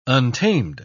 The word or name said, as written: untamed